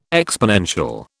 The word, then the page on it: exponential: British English